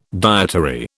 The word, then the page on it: dietary: British English